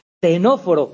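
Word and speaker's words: ctenóforos: Pues fijaos, la clave es, de estos primeros grupos, los que tienen una C que no se pronuncia, ¿vale? Tenóforos con C delante y nidarios con C delante son en los que aparece la neurona.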